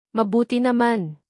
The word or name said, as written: mabuti naman